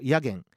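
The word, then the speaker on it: 薬研: ヤゲンと読むが東雲はヤッケンと呼んでいるそうです。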